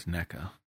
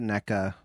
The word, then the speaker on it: NECA: Which I think the guy that was talking about it works for, is it Nika? Nika Toys? Is that who makes the... – NECA. – Yeah, I NECA? Think we pronounce it NECA.